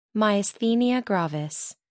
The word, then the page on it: myasthenia gravis: American English